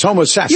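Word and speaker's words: Homosassa: That's not how you say it. It's Homo Sassa.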